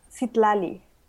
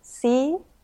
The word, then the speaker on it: Citlali: My name is pronounced Citlali. It's like if you divide it in three syllables, it's Citlali.